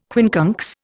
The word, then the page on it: quincunx: Indian